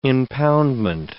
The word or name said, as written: impoundment